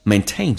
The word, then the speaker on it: maintain: 维系 maintain 这个单词，这两个，那么其实啊，它有两个 a 这个发音，重音是在后面，所以呢，前面的 a 它不是属于主重元音，它的发音就没有第二个 a 那么的夸张，比较的扁平，嘴型比较小一点，就变成 maintain, maintaining, you see, maintaining those unnecessary, 美式发音这个单词啊，跟英式发音有点区别。